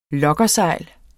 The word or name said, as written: luggersejl